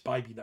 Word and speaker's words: Bibee: It's Bibee, not Bibby. I've said it incorrectly in the past.